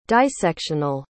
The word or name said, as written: dissectional